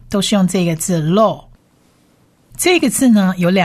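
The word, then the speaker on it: law (לא): 拉美这个字呢，发音上发了的音，而左上方呢有一个黑点，它是长母音 O，所以这个念 law， law， law。第二个字母呢是 Aleph，末音字母不发音，所以整体两个字母合起来就是念 law 的音， law， law，意思是不可，不可。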